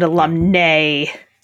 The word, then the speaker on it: alumnae: The main place where this pronunciation thing comes up in people's lives is the question of how do you pronounce alumni with an I or alumni with an AE? And I pronounce them alumni and alumni. Alumni is male, alumni is female, but most people pronounce it, I think, alumni. If it's nigh on the end. – Right. – And alumnae. – Yeah. – For an AE.